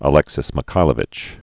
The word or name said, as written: Alexis I Mikhailovich